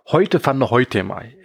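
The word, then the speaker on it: Hoyte van Hoytema: nämlich den Niederländer Heute van der Heutema. Ich habe das jetzt wahrscheinlich komplett versammelt. – Das hast du super ausgesprochen, finde ich. Hast du vollkommen richtig Dankeschön.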